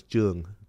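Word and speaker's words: Truong: The correct, that I always go by Trung because that's the easiest, right? But the correct pronunciation of my last name is Chung.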